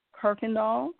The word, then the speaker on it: Kirkendoll: You can also catch me on my Instagram page, but on my personal page, which would be Fechina Kirkendall. That's spelled F as in Frank, O-U-C-H-I-N-A. Last name is K-I-R-K-E-N-D-O-L-L.